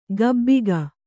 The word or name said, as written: ಗಬ್ಬಿಗ